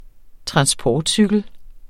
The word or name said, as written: transportcykel